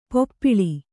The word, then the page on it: ಪಪ್ಪಳಿ: kannada